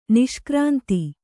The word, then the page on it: ನಿಷ್ಕ್ರಾಂತ: niṣkrānta